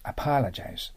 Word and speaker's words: apologize: Apologize と発音します。 Apologize。これはですね、アクセントに気をつけないといけません。ね、 Apologize です。